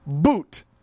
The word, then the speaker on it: but: Boot. Not butt. It's spelled B-U-T, but it's pronounced boot.